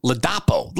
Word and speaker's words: Ladapo: Lopato. Lopato. Did I say that right? No, I'm Joe Biden. Ladapo. Ladapo.